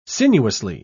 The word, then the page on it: sinuously: British English